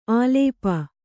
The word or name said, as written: ಆಲೇಪ